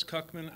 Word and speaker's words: Kucman: My name is Terrace Cuckman. I'm a resident of Concord, and my name is spelled T-A-R-A-S, last name Cuckman, K-U-C-M-A-N.